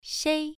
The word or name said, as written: shei1